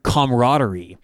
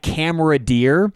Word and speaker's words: camaraderie: There was a commercial that had the word camaraderie. And if you look up the word camaraderie, it's spelled like camera deer, and no one else was in the studio with me.